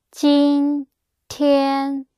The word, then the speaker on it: 今天: So today is jin tian.